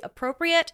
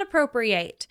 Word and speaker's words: Appropriate: I wanted to just make it known, because since recording this, I have realized that the pronunciation of this play is actually appropriate, not appropriate. And I feel like that is a difference that definitely needs to be made known.